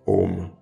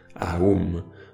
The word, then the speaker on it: Aum: Et pranava, c'est le nom du mantra Om, ou plus précisément prononcé Aum.